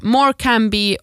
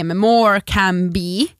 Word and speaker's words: Morecambe: Och Morecambe säger man tydligen Morecam. Alltså M-O-O-R-K-A-M. Morecambe.